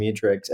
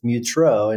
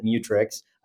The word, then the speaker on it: Mutrux: Our family pronounces it Mutrix. But whenever I'm on the phone with someone, I'll say Mutrix. It looks like Mutrux.